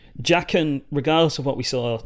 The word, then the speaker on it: Jaqen: is it Jaqen? – Yeah. – Jaqen, the waif wanted to kill Arya.